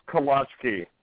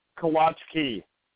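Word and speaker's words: kołaczki: And being Polish, it's pronounced kolachki. – Do what? – Kolachki. – There is no extra K in there. – But that's how it's pronounced.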